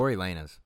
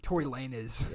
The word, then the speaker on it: Tory Lanez: Tory Lanez. – Well, you don't even know say his name right. Tory Lanez.